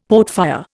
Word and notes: portfire: Indian

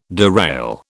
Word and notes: derail: British English

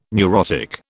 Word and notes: neurotic: British English

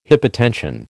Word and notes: hypotension: American English

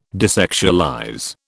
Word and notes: desexualize: British English

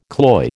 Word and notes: cloy: American English

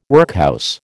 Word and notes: workhouse: American English

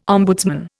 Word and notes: ombudsman: Indian

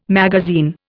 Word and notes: magazine: Indian

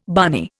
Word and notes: barney: Indian

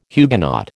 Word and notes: Huguenot: American English

